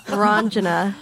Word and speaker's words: Ranjana: But Rajana. – Ranjana. – Ranjana.